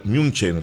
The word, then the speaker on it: München: Los posteos de las redes sociales del equipo germano, del equipo bávaro, el Bayern München, como se pronuncia, el Bayern Múnich, han sido positivos en torno al accionar del colombiano desde el equipo bávaro.